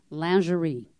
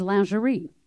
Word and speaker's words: lingerie: For example, lingerie. The first acceptable way to say that, the first, is lingerie. Then it's lingerie, lingerie, lingerie.